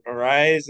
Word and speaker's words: Arraez: Now, I did see yesterday that Luis Arraez, is that how you pronounce it, the Twins guy? – Luis Arraez. – Arraez was Arise, basically, yeah.